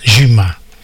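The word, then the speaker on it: Zima (Зима): La stazione di Zima, però si pronuncia Zima in russo.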